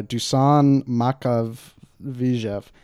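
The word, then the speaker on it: Makavejev: Sweet Movie by Dusan Makhachev. And this is also great pronunciation. Right? – I'll speed it up because you got it right. You just said it slow.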